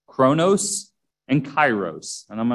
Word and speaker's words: kairos: Chronos is spelled C-H-R-O-N-O-S. C-H-R-O-N-O-S. Chronos, kind of like chronological. And the second word is kairos. It's spelled with a K at the beginning. K-A-I-R-O-S. K-A-I-R-O-S.